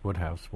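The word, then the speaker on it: Wodehouse: Well, of course, we could ask why his name is pronounced Woodhouse when it's W O D E. – Looks like Woodhouse.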